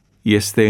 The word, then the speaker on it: este: În ceea ce privește pronunția formelor verbului a fi, e, este, eram, erai, erați, erau, se pronunță cu IE inițial, e, deși se scrie cu E.